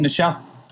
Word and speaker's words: Nichelle: Nichelle.